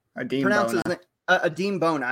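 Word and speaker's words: Adem Bona: Freshman of the Year, another UCLA guy, Adama Bono, or shit. Adeem Bona. I don't know why I kept saying that wrong.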